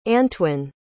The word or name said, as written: anntoin